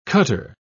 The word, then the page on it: cutter: British English